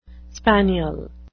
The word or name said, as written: spaniel